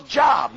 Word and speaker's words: Job: J-O-B spells Job. Now, I learned that in school. She said, honey, it's pronounced Job.